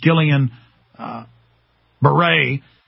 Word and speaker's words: Guillain-Barré: So they're saying, get ready for people having strokes, having miscarriages, getting Gillian, I always mispronounce it. It's not Guillain-Barré's, it's Guillain-Barré.